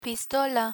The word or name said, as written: pistola